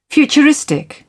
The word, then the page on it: futuristic: British English